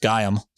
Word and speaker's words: Gaiam: Our name, Gaiam, pronounced Gaiam, is a fusion of Gaia, the name of the ancient Greek Mother Earth goddess, and I am.